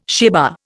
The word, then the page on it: sheva: Indian